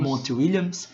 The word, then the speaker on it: Monty Williams: Todos os jogadores têm no playbook do, do treinador, me ajuda com a pronúncia, Monty Williams. Monty Williams.